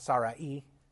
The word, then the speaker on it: Sarai: When we think of Sarah, previously Sarai, or actually probably best pronounced Sarai in the Hebrew,